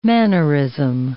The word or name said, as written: mannerism